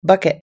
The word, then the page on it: bucket: American English